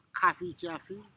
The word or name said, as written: kafijafi